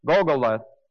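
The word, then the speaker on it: Gulgoleth: Golgotha, the Hebrew word, actually that is a Hebrew word, but the actual Hebrew word is spelled Golgoleth, G-U-L, G-O-L-E-T-H, G-U-L-G-O-L-E-T-H, Golgoleth, and that means a skull or head or a pole, P-O-L-L, pole.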